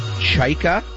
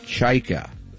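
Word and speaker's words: Chayka: John Chyka, that is the correct pronunciation. Just think of chai lattes. Chyka. No? Yes, Chyka.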